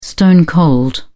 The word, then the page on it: stone-cold: British English